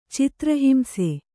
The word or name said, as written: ಚಿತ್ರಹಿಂಸೆ